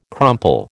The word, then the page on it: crumple: American English